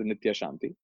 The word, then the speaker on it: Nithya Shanti: So normally Nithya is spelled N-I-T-Y-A, but she said add the H, just for whatever numerological reason. And I said, H is nice, H is for happiness. So I spell it as Nithya Shanti, even though the pronunciation is Nithya Shanti.